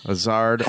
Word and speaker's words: Hazard: Hazard.